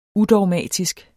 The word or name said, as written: udogmatisk